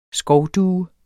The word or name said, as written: skovdue